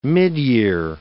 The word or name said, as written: midyear